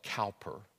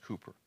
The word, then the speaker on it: Cowper: Or it looks like Cowper, but it's pronounced Cooper.